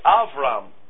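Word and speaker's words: Avram (אברם): That is the month Aviv, spelt A-B-I-B in English. But as Tel Aviv, the city Israel gets that name. In the scripture you will read Tel Aviv, A-B-I-B, because that's how that letter is to be pronounced in Hebrew. Not to give you a Hebrew lesson, but that's why Abram is Avram.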